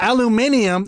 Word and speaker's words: aluminium: Aluminium. It's not a mispronunciation. – Aluminium. – They're adding a letter.